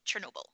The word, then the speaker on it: Chernobyl: Are you going to watch Chernobyl? Am I pronouncing that correctly? – Yeah, I think I probably will.